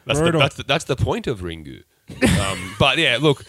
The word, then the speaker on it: Ringu: It's like Ringu. – You pronouncing that in Japanese. – That's what the film's called, Adam. Sorry. – Is it? – Yes. – It's called Ringu.